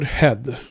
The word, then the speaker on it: Head: Han heter ju Head i efternamn och det uttalas ju så. Mm. Huvud. Perfekt. H-E-A-D.